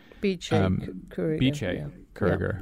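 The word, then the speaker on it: Bice Curiger: you did an interview with the Tate's Bice Kuriger, who I hope I'm saying that right. – Biche Kuriger. – Yeah.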